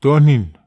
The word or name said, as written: tonhin